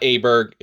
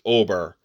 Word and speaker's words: Åberg: It's not Åberg, it's Åberg. No G. A sounds like an O. All right, so we'll go with that moving forward. But Ludvig Åberg is playing this week.